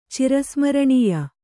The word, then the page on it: ಚಿರಸ್ಮರಣೀಯ: cira smaraṇīya